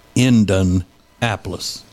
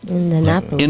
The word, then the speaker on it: Indianapolis: That's how I told y'all they pronounce it, not Indianapolis, but Indianapolis. – Indianapolis. – Indianapolis. They leave that E sound out of the middle of it.